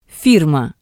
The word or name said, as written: firma